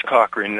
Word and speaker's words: Cochrane: No, it's Cochrane, that's right.